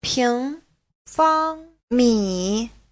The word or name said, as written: ping2fang1mi3